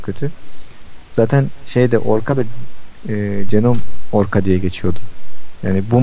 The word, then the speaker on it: GNOME: Bizim için en erişilebilir olan masaüstü genom, genom diye okunuyordu herhalde. G-N-O-M-E diye yazılıyordu yanlış hatırlamıyorsam.